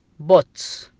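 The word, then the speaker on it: put: حالات شاذة تكتب اليو وتنطق بالضمة اليو، مثل كلمة بوت، بوت ذا جلاس بليز، بوت ذا جلاس، بوت ذا جلاس داون، بوت تنطق ب بعدها يو، تنطق بحرف الضمة كأننا حنا ضمينا حرف البي، بو بوت.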